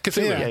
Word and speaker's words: Cthulhu: He being Fulu. How do you say it? That's who I was thinking of. The tentacle monster on the throne. Yeah, you know. – Cthulhu. – Yeah. There we go.